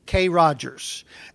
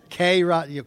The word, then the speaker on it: Kroger: K. Rogers and buy grain. You know what a K. Rogers is? Kroger. Got it. Okay. Yes. K. Rogers. Kroger. Okay. If you pronounce it phonetically, it'd be K. Rogers.